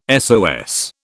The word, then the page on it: SOS: British English